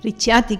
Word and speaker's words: Ricciati: Ricciati, que es difícil pronunciar esto. – Sí, pues italiano. Siempre que lleva una doble C, es la CH en español.